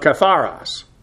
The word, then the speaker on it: katharos: Who call upon the Lord from, preposition ek here, with the ablative of source of katharos. K-A-T-H-A-R-O-S. Katharos, the accent is on the last syllable.